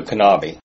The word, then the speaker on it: Kanabi: He pronounces it with the K, so Kanabi.